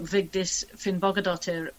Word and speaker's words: Vigdís Finnbogadóttir: We have also in the book Vigdís Finnbogadóttir from Iceland. – Oh, great pronunciation.